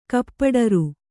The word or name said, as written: ಕಪ್ಪಡರು